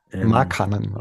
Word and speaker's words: Markkanen: Ich weiß übrigens gar nicht, ob ich Markkanen richtig ausspreche, den alten Finn. – Markkanen.